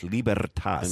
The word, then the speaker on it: Libertas: Yeah, watch the special, and I'm going to go as Latin as I can go. I'm going to build a wall around my pronunciation of this. Libertas.